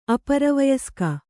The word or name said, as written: ಅಪರವಯಸ್ಕ